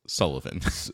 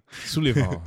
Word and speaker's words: Sullyvahn: I fought a boss called Pontiff Sullivan. – I have not gotten to him. – It's spelled Sullyvahn. And it's clearly just Sullivan. – Sullivan.